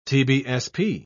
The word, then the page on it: tbsp: British English